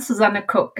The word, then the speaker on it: Susanne Cook: I'm Susanne Cook, and thank you so much for having me here.